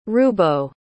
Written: rubo

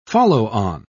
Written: follow-on

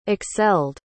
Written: excelled

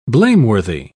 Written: blameworthy

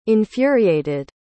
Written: infuriated

